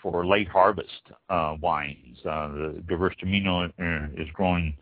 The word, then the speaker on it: Gewürztraminer: Gewürztraminer. This is probably the toughest wine to pronounce and long spelling on it. Spelled G-E-W-U with the little two dots above it, the German two dots. R-Z-T-R-A-M-I-N-E-R. Gewürztraminer.